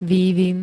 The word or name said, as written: vývin